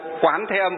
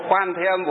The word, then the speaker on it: quán: Ví dụ Nam Mô Quán Thế Âm Bồ Tát hay Nam Mô Quan Thế Âm Bồ Tát đều được cả. Nhưng mà nếu mà đọc đúng thì phải đọc là quán thì đúng hơn.